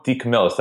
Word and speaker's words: DeCamillis: So I totally forgot, and I want to butcher his name, Joe DeCamillis.